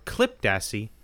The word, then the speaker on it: Klipdassi: We'll talk about what it is in a second, but it's also called the Dassey. Which I imagine that's not how it's pronounced, but it's short for Klipdassi, which is Afrikaans for cliff badger.